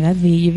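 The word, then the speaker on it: Gadsby: Tú tampoco puedes pronunciar Me cuesta caleta decir Gatsby. – Es como el gran Gatsby. – Oye, qué fuerte Hannah Gatsby.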